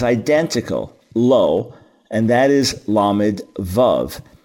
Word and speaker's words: lo: There is a word lo, which the Hebrew letters Lamed Aleph spell it lo, and it means no, not.